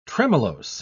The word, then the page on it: tremellose: British English